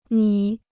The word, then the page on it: 米: mǐ